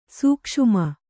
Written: ಸೂಕ್ಷುಮ

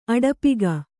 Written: ಅಡಪಿಗ

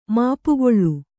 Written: ಮಾಪುಗೊಳ್ಳು

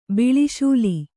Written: ಬಿಳಿಶೂಲಿ

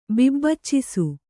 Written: ಬಿಬ್ಬಚ್ಚಿಸು

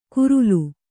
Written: ಕುರುಲು

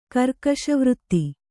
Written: ಕರ್ಕಶವೃತ್ತಿ

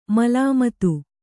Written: ಮೇಲ್ಮಿತಿ

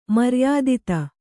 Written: ಮರ್ಯಾದಿತ

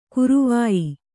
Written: ಗರವೊಯ್

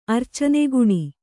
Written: ಅರ್ಚನೆಗುಣಿ

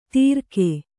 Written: ತೀರ್ಕೆ

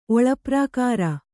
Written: ಒಳಪ್ರಾಕಾರ

